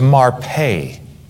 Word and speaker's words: marpe: And the word health, pronounced in the Hebrew, is marpe.